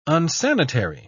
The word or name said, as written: unsanitary